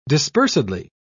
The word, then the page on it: dispersedly: British English